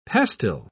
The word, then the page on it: pastil: British English